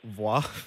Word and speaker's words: Voix: It's spelled V-O-I-X. How do you pronounce that? – Voix. – Voix. Yes, Saint Voix.